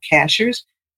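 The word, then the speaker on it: Cashiers: Lots of UFOs over a town called Cashers, which is spelled like a cashier at the grocery store.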